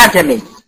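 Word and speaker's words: أأعجمي: الوحيده اللي بيسهلها اعجمي. اسمها الهمزه المسهله، يعني بنطق الهمزه ما بين الهمزه وحركتها. لو الحركه مفتوحه يبقى هتبقى قريبه من الفتح، لو مضمومه قريبه من الضم، وهكذا.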